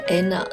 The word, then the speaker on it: Einar: 那这位老板叫做 Anna。呃，E I N A R，这应该是冰岛当地语言的发音。